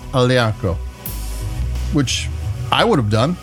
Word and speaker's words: Aglianico: And then we go to the Aglianico, and that's A-G-L-I-A-N-I-C-O. And there again, since it's a G-L, the G is silent,